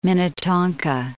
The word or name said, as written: Minnetonka